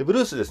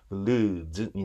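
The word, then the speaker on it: blues: でブルースですね、もともと英語の発音は実際にブルーズなんですね。ブルーズなんで、 Z なんですよ、最後の発音がね、 L とブルーズになるんですけど、ブルーズっていうと、ブルースと違うんですかっていうところから説明しなきゃいけなくなるんで、まあもともと日本人が普及している言葉としてブルースというふうに扱いたいと思います。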